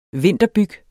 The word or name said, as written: vinterbyg